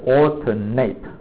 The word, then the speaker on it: alternate: 这个当动词的时候是念作 alternate，那当形容词的时候念成 alternate 就是隔一个的，啊，这样懂吗？